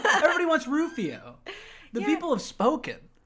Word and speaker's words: Rufio: fucking B, three syllables, one word. Rufio.